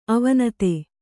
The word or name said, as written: ಅವಿನೀತ